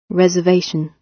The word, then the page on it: reservation: British English